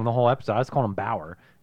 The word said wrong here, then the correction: Brower: It's Brower with it. – Brower? – Like your brow.